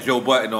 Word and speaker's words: Budden: Now we have guests that don't don't come on or don't smoke or drink, but it's because like Joe Budden, we had Joe Budden on.